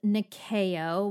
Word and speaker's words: Nyakio: Next brand we are going to talk about is Nikeo, which is spelled N-Y-A-K-I-O Beauty. And Nikeo is a vegan, cruelty-free, handmade, small-batch beauty brand that uses cold-pressed oils crafted with ethical, sustainable, and natural ingredients.